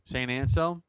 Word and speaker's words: Anselm: Tell us where East Meadow, New York is, and that college that's there, which is what, St. Anselm? Is that how you say it? – St. Anselm. – Anselm. Okay. Yeah. Anselm. Is that right? – Yeah.